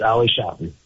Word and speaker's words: Ali Shafi: Yeah, it's Ali Shafi, just like that. – Just a little more emphasis on the Sha.